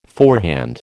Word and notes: forehand: American English

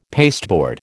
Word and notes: pasteboard: American English

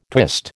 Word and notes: twist: American English

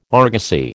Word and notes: argosy: American English

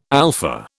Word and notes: alpha: British English

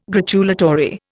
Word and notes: gratulatory: Indian

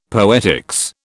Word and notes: poetics: British English

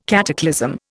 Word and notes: cataclysm: Indian

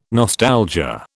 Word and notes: nostalgia: British English

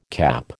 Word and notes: CAP: American English

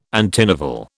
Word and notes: antinovel: British English

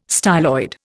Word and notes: styloid: Indian

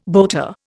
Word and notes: boater: Indian

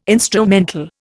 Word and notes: instrumental: Indian

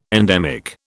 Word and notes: endemic: British English

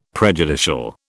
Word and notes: prejudicial: British English

